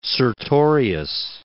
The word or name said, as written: Sertorius